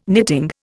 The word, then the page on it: knitting: Indian